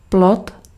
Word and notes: fruit: /ˈfʁɥi/; /fʀɥi/

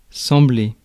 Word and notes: sembler: France; /sɑ̃.ble/

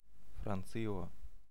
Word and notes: France: /fʁɑ̃s/

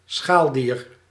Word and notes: crustacean: American English; [kɹʌs.ˈteɪ.ʃən]

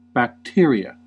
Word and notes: bacteria: American English; /bækˈtɪɹ.i.ə/

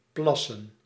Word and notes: secrete: /sɪˈkɹiːt/; American English

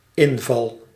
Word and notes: offensive: /ə.ˈfɛn.sɪv/

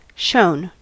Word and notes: shone: American English; [ʃoʊn]

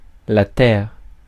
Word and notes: Earth: /ˈɜːθ/; /ˈɝθ/

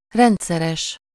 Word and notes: regular: English

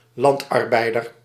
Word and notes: peasant: /ˈpɛzənt/

